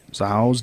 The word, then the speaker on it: Zahau: Z-A-H-A-U... How do you think you spell that? How do you think you pronounce that? Zahau? Zahau's death? – Zahau.